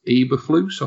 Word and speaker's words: Eberflus: And I think, you know, Matt Eberflus, I think I've got that pronounced correctly. The defensive sounds right to me.